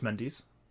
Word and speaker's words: Mendes: I believe it's Mendes. – Like testes? – Yes.